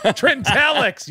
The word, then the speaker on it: Trintellix: I took some beta blockers, a couple Trintellixes. – Trintellix, you bastard. Say it right.